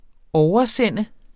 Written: OVERSEND

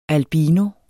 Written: albino

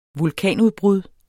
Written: vulkanudbrud

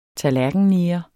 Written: tallerkenneger

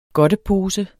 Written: godtepose

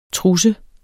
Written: trusse

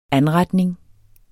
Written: ANRETNINGER

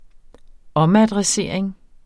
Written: omadressering

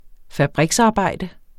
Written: fabriksarbejde

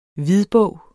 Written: hvidbog

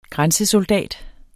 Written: grænsesoldat

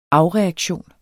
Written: afreaktion

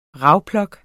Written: rawlplug

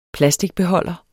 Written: plasticbeholder

